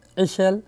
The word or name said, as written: newborn